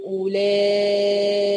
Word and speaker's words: أولئك: خلي بالنا أولئي الواو هنا ساقطة مش هنطقها، أنا بنطق بدخل من همزة مضمومة بتاعت أو على لام ألف ممدودة عشان وراها حرف الهمزة. أولا أولا ما بقولش أولا، لا بقى على طول أولا.